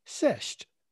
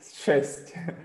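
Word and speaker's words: šesť: Because I can never remember whether it's sh at the beginning or ch. You have this ch sound at the end of a word, don't you? T with a bit of a tick. Ch. Is that right? Ch. – Try sh. Šest.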